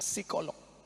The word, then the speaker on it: psikolog: Tulisannya psikolog. Bacanya P-nya hilang, psikolog.